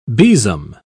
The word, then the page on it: besom: British English